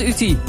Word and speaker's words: Uti: Goedenavond, Uti. – Goedenavond. – Zeg ik dat goed, Uti? – Dat is goed, zo.